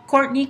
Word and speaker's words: Kourtney: Me llamo Courtney Kaczynski. – ¿Cómo se escribe su primer nombre? – K-O-U-R-T-N-E-Y.